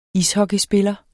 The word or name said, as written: ishockeyspiller